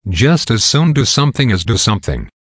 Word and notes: just as soon do something as do something: American English

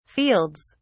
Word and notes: fields: British English

